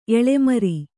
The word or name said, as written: ಎಳೆಮರಿ